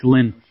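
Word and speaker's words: Linne: I don't know if you pronounce his last name. It's L-I-N-N-E. It's Lynn.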